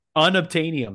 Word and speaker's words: unobtainium: You said that the wrongest way possible. That sounds like an old civilization, Mesopotamia. Unobtainian? What? – How do you say it? – Unobtainium. Like there, it's unattainable.